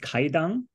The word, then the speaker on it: Kaidan: It's pronounced Kaidan. – Kaidan. Okay. – Yeah, the W is there because of a sort of, I think it's called like an archaic transliteration in the Hepburn style of translating Japanese into English, but the W shouldn't be there.